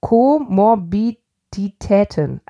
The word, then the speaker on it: Komorbiditäten: Und es gibt eine Komorbidation. Ich sag dieses Wort nicht mehr, Leute. Ich kann dieses Wort nicht aussprechen. – Co-was? – Komorbiditäten. Also das etwas Komorbiditäten.